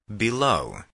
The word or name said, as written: below